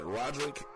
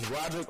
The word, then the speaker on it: Rodrick: You can find me on Facebook at Rodrick. I'm going to spell my middle name for you. It's pronounced Lil Wayne, but it's not like the rapper. So it's Rodrick, R-O-D-R-I-C-K, no E, L-U-A-N-E, Butler, B-U-T-L-E-R.